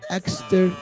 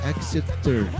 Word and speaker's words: Exeter: Exeter, Exeter, Exeter, se escreve E-X-E-T-E-R, Exeter City.